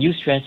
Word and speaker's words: eustress: And eustress is spelled E-U-S-T-R-E-S-S. The idea of eustress means that it's a positive aspect of stress, meaning we focus on the creativity and curiosity under stress. But very often people tell me, you stress me out, as in Y-O-U, okay, which is not how it's spelled.